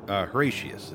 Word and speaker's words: Horatius: Horatius. It's like Horatio with a U.S. instead of an O.